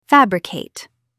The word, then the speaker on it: fabricate: Stress the first syllable. Fabricate. Try saying it aloud.